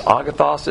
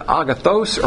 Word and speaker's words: agathos: agathos, A-G-A-T-H-O-S. You can pronounce this word agathos or agathos. I like the southern version pronunciation. Even though it's an O, you pronounce it with an A.